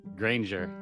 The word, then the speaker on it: Granger: Granger, yeah.